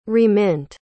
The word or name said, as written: remint